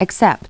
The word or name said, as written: accept